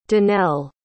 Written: donelle

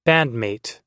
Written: bandmate

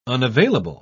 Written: unavailable

